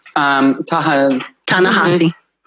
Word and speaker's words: Ta-Nehisi: and I'm going to say his name wrong, Ta-Nehisi Coates, Ta-Nehisi.